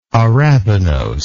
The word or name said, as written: arabinosic